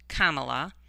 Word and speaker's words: Kamala: adults have a favorable opinion of Vice President Harris, whose name is pronounced Kamala, while about half have an unfavorable opinion.